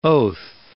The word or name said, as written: oath